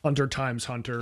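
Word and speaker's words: Hunter x Hunter: You don't pronounce the X, by the way, for some reason. I don't know why. – Hunter times Hunter.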